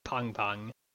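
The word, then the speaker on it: Pengpeng: Also, because I was listening to a book and need a correction, the name is actually pronounced Pong Pong. Not like more like the word pong instead of peng, like penguin.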